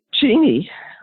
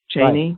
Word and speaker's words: Cheney: Dick Cheney, which is how you actually pronounce his last name, often corrected people, but the media never got his last name right. They said it more like Lon Chaney, you know, like the actor, Chaney, right, with an A.